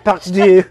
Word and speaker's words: deux: Part deux.